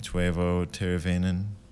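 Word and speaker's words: Teuvo Teräväinen: I don't know how to say this guy's name. Tuivo Teravainen. – That was pretty close.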